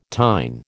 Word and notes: tine: American English

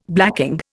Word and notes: blacking: Indian

